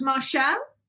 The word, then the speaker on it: Marchal: And you were called Lynda, Mar- is it pronounced Marshall? Yes, very posh.